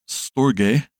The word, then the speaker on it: storge: Next week, we will break into the next chapter, which deals with the love of affection called storge, with a hard G in the original Greek.